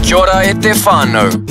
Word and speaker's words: kia ora e te whānau: Hey, I recently learnt the right way to say hello family in te reo Māori. Is kia ora e te whānau? – Mean, I'll make sure to remember that.